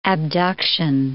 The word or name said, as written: abduction